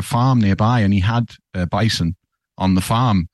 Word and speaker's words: bison: And I learned that the French for bison is bison. – That would totally make sense. – Yeah, it's bison. It's the same spelling, just different. – Yeah, it's just bison. – The emphasis on the final syllable in the French way.